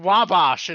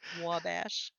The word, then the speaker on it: Wabash: Oh, spirit, I can't find Wabash, and I'm stuck here forever. – Wabash. Not Wabash.